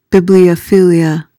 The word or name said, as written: bibliophilia